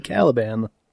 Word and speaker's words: Caliban: So I always pronounced his name as Calibian. Makes no sense, right? Because you look at it, it's like, nope, that, that is clearly Caliban.